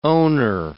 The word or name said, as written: owner